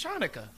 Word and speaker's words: Chanukah: I'm sorry, I'm not being disrespectful, but how do you really pronounce that? Hanukkah, when there's a C and there's... – Because it's Chanukah. It's like knife, you know? Don't you say knife? – Right.